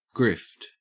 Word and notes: grift: Australian English

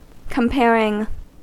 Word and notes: comparing: American English

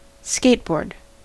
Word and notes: skateboard: American English

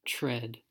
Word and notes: tread: American English

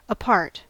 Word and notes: apart: American English

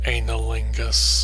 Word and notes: anilingus: American English

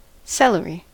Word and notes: celery: American English

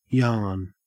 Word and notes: yarn: Australian English

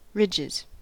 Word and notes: ridges: American English

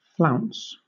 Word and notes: flounce: British English

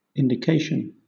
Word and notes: indication: British English

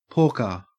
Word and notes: porker: Australian English

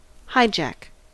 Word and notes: hijack: American English

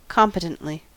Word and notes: competently: American English

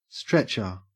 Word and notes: stretcher: Australian English

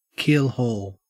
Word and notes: keelhaul: Australian English

